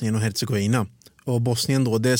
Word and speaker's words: Herzegovina: Eh, det uttalas eh, Herzegovina på bosniska.